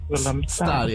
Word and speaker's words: Willemstad: I pronounced it wrong. Willemstad. Yeah. Okay, sorry. I said Willemstad.